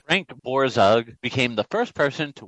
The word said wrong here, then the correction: Borzage: Borzage, not Borzug. Get his name right.